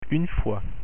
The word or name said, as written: fois